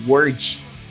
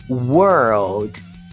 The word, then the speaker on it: world: A galera tá pronunciando aí fora como Word, que é palavra. Então fique atento, olha só essa pronúncia. World. World, ok?